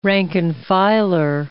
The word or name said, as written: rank and filer